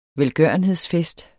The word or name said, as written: velgørenhedsfest